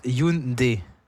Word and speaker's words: Hyundai: En français, Hyundai, donc, se prononce Hyundai. Le H initial est muet. Le Y se lit comme un I appuyé et la finale se prononce comme le son D.